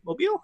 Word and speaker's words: mobile: Mobile? How do you say that word? – Yep. – Mobile? – Yeah, mobile. – Mobile? Like Mobile, Alabama? – Yeah.